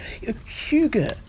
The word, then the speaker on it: hygge: Hygge.